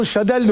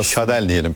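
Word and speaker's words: Chadaille: Shaddai de olur, Shadel de olur bu. A'yı E okunur Fransızca'da. El Shaddai. O Shaddai de diyebilirsiniz diyor. Yani biz ne diyelim? Yani. – El Shaddai adı aslında. – Shaddai diyelim peki.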